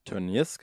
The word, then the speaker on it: Tönnies: Ähm, wohingegen halt bei äh, Tönnies, habe ich richtig gesagt? Tönnies, genau.